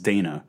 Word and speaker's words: Dana: And I always thought her name was Dinah. It's Dana.